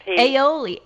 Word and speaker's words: aioli: Aioli. – Aioli.